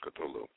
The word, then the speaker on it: Cthulhu: Now, Cthulhu was one of the, of course, there's several different ways to pronounce Cthulhu, but I think most everybody says Cthulhu.